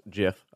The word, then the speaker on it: GIF: Everyone I've talked to, with the exception of the GIF that you sent me, and it is pronounced GIF, I don't care what anyone says, that no one knows about gigabytes.